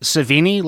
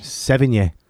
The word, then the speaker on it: Sevigny: Is it Savini, like Tom Savini? – Sevigny. – Oh, is it Sevigny? – I don't know. I was going to ask you guys how. I don't know how to pronounce it. – Chloe Sevigny. – Oh, I always said Chloe Savini.